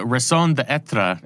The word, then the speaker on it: raison d'être: It's the raison d'être. Am I pronouncing that correctly? – Close enough for me.